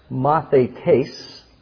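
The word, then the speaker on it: mathetes (μαθητής): The Greek word disciple is pronounced mathetes.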